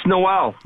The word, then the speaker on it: Noel: Is it Noel? – It's Noel. – Noel, like the holiday.